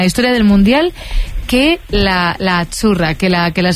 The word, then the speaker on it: Azzurra: Es que Azzurra. Sí, como porque pizza, la Z no es la Z como decimos en catalán tan sonora, no. Es Azzurra. – Muy bonito, muy bonito. – Es como pizza.